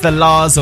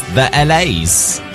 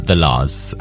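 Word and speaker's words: The La's: There she goes, it's the Lars on Gorgeous Radio. I heard another radio station pronounce this as the LAs. That's a new one. It is, I can confirm, the Lars.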